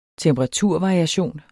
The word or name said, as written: temperaturvariation